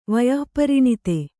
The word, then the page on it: ವಯಃಪರಿಣತ: vayah pariṇata